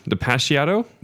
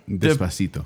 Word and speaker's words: Despacito: Depeciato? – Despacito.